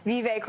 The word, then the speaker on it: Vivek: If they want to be vice president, they're going to have to learn how to say his name correctly. It's Vivek.